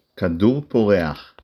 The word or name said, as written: כדור